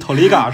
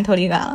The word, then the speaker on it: TRIGGER: 日语发音 Toliga 是吗？学到了。 – 就是习惯了，就是因为日本人都喊托利嘎，所以就习惯喊托利嘎了。